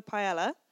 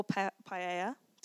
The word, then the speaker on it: paella: And this book had an international section, which included a recipe for paella, or paella, depending on your pronunciation.